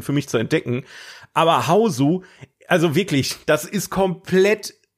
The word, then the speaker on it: Hausu: Ähm, es geht um den japanischen Film von 1977, Hausu. Wie schreibt man das? H, also wie Haus, ne, auf Deutsch, ja. mit U von 1977.